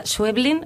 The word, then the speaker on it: Schweblin: Hoy nos vas a poner voz a un cuento, sí, de Samantha Schweblin, ¿lo pronuncié bien? – Perfecto. – Sí, perfecto.